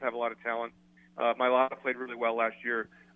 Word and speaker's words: Mailata: Mailata? What is it? – Jordan Mailata. Jordan Mailata.